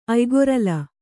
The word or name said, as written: ಅಯ್ಗೊರಲ